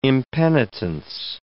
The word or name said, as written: impenitence